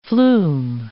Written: flume

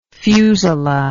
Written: fusula

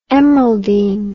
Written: emeraldine

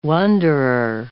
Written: wonderer

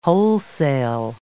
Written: wholesale

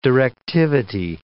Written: directivity

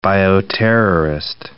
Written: bioterrorist

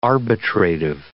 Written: arbitrative

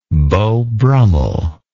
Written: beau brummell

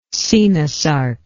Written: coenosarc

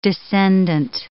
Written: descendant

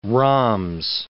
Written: rhombs

